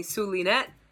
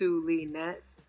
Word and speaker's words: Zulynette: First, I am Zulynette. You may call me Zulynette. It's Zulynette. Wait, I'm doing this backwards. Zulynette. Whatever way the ball bounces, that's... Anyway, it's three syllables.